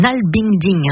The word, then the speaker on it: nalbinding: C'est le nalbinding.